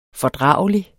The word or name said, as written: fordragelig